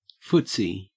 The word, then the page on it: footsie: Australian English